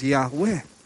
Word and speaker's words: Yahweh: The Hebrew word obviously would be YHWH, which we pronounce as Yahweh.